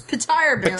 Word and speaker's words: Petyr Baelish: I guess in the audiobook they pronounce Peter Baelish as Petyr Baelish. – Petyr Baelish.